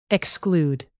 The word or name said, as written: exclude